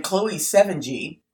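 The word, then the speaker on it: Chloë Sevigny: And Chloe Sevigny, oh my gosh, I can't believe I actually said that right.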